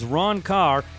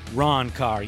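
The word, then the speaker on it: Ron Karr: My guest this evening is Ron Carr, the Ron Carr.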